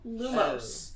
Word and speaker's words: Lumos: So the closest you have in your accent to an O Lumos. – Sound. You just don't want to be lazy and fall off that last syllable. Lumos.